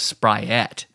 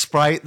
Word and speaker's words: Sprite: I always thought it was Spriette. Because that's how my family says it, Sprite. – Really? – Yeah. – That's an odd Like the T and the E are the other way around. – That's weird. – So, yeah. Yeah. – But do you think if they found out that it was actually pronounced Sprite, they'd all of a sudden be a 7-Up family? – Maybe.